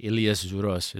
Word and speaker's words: Ilias Zouros: So, Ilias Zouros is the Greek term, pronunciation for that.